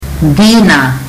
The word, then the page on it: dina: fiji